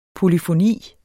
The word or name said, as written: polyfoni